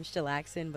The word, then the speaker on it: Challxn: Well, technically, so you don't say my name, Challxn, but that's how you find it.